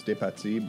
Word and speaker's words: DePazzi: It is a French name. It's DePazzi, but got you. – I'm an English fella, so there's... Butcher it a little, and DePazzi.